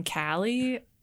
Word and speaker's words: Kallie: I mean, growing up with the name Kallie, people always spelled it wrong or pronounced it wrong.